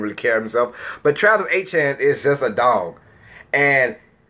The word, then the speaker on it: Etienne: Now, people call him Etienne, but, you know, we're from Louisiana. It's pronounced Etienne.